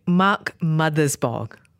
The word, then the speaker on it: Mark Mothersbaugh: The score was done by Mark Mothersbaugh.